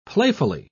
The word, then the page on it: playfully: British English